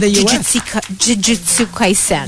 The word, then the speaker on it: Jujutsu Kaisen: Jujutsu Kaisen.